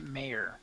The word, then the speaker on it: Maher: I guess his name is pronounced Mayer.